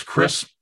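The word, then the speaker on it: Kr'hi'sse: My name is Chris. That'll be great. – 5 stars. – How do you spell that? – K-R apostrophe H-I apostrophe S-S-E. That last S and E are silent.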